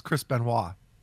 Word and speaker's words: Benoit: Yeah, that's how it's spelt, but it's Chris Benoit.